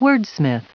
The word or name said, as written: wordsmith